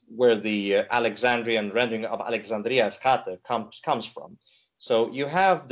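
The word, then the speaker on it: Alexandria Eschate: I can't pronounce this Greek name, so I need your help here. – Yeah, it's Alexandria the furthest, which would be Alexandria Eskate.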